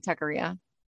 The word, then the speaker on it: Taqueria: Did I say that right? Taqueria. – Yeah.